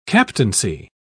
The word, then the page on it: captaincy: British English